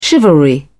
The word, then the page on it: chivalry: British English